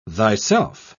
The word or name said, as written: thyself